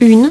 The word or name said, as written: une